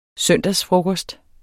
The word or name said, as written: søndagsfrokost